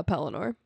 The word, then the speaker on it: Pelennor: Yeah, Pelennor.